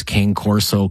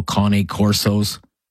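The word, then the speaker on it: Cane Corso: Terrible story involving an 11-year-old boy who was mauled to death by a pair of, I've heard it pronounced two ways, King Corso, Cane Corsos.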